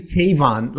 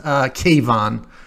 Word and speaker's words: Kayvon: And just make sure I get is it pronounced Kevin? I want to make sure. Or no, Kayvon? It's actually Kayvon. Like Kayvon. It's pronounced okay. Key to the van, but it's pronounced Kayvon.